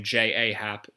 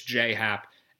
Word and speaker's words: J. A. Happ: Um, and then the other thing with the Yankees is that they're facing a left-hander, J. Hop, who doesn't pronounce his name J. A. Happ as we established last year, but it's J. Happ, and he's not bad.